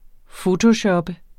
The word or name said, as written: fotoshoppe